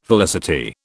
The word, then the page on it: felicity: British English